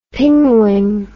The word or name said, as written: pingwing